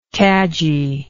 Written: cadgy

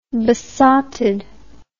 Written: besottedness

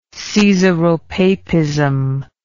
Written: caesaropapist

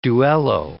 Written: duello

